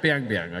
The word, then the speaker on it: Biang Biang: How would you say it? – How's it spelled? – B-I-A-N-G. – Yeah, Biang Biang, I guess.